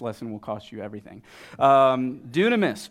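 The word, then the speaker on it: dunamis (δύναμις): It's actually the Greek word dunamis, D-U-N-M-I-S, or D-U-N-A-M-I-S, excuse me. So dunamis. Look at the person next to you and say it's pronounced dunamis.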